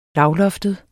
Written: lavloftet

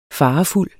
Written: farefuld

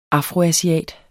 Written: afroasiat

